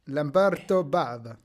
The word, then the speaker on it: Lamberto Bava: Lamberto Bava.